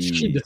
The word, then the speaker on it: Sidhe: On a côté magazine un petit nouveau qui s'appelle alors Seed. – Je sais jamais la prononciation. – Moi je mets Seed ou Seed, ça dépend. – Je crois que c'est Sheed. Je crois que c'est Sheed. – Voilà, on vous le met. – S-I-D-H-E, qui est un nouveau magazine.